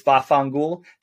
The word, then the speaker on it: vaffanculo: Vaffanculo. Or vaffanculo, and again, forgive me the pronunciation, it's most often heard as vaffangul.